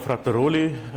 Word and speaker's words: Frattaroli: I'm Nicola Frattaroli. I'm from Switzerland, which explains why the first name is pronounced the French way. The second name is Italian, and the accent is German.